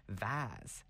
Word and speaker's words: vase: People in British English regions like Canada and Australia have a tendency to say vaz, as in rhyming with has.